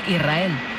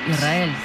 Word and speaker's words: Israel: Israel, ¿no? – Y la S con la R es difícil de pronunciar, la gente dice Israel. Es sin que suene la S, Israel, sí, es difícil de pronunciar.